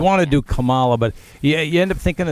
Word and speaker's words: Kamala: That's how I think of that in my head, the Culture Club song, and it gives me the way to say it, Kamala. That's how you pronounce her name.